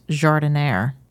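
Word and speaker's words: giardiniera: I think it's so weird how it's pronounced Jardiniere. – I hope I'm saying it right. – No, you are. Or at least you're saying it the way people here in the U.S. say it.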